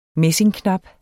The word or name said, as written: messingknap